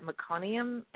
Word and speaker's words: meconium: And when she finally emerged, the umbilical cord was wrapped around her neck, and they found some, I always mispronounce this, but meconium, I think it is.